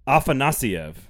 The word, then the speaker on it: Afanasyev: I thought Afanasyev. – There you go. – That's exactly what I said. – No, that's the first time you've said it correctly.